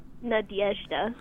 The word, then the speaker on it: Nadezhda: That's not exactly how she pronounced it. – Nadezhda. – Yeah, it's more like Nadezhda. Yeah, there's like a Y sound in there.